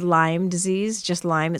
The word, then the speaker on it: Lyme: But here in the Midwest, we have found, after being here the last couple of years, that a lot of people pronounce it Lyme's disease, and it's just Lyme, just no S. We only need one. We don't need two.